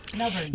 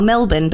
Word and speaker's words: Melbourne: Melbourne. – Yes, that's right. – Oh, is it Melbourne? Tell me, how do you say it? – Well, Melbourne, but we don't pronounce our R's.